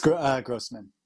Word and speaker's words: Grossman: Is it Grossman or Grossman? – It's Grossman.